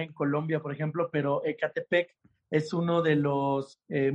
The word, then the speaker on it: Ecatepec: Espero que haya pronunciado bien Ecatepec. – Mm, mm, lo dijiste súper bien, Jonathan. Ecatepec está.